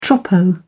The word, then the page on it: troppo: British English